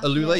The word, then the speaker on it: AlUla: And I always get it pronounced wrong, Alula. – Alula, yeah, that's how you pronounce it.